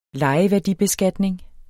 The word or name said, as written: lejeværdibeskatning